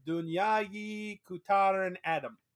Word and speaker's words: Dünyayı Kurtaran Adam: Dünyayı Kurtaran Adam. – Very good. I like the pronunciation. Well done.